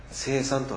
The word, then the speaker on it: 聖餐: 聖餐式とも言われますけれども、聖なるという聖という字に飲み食いを意味する晩餐会の餐と書いて聖餐と読みます。